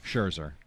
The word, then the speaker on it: Scherzer: Scherzer.